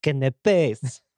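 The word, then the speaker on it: canapés: No, it's pronounced canapes.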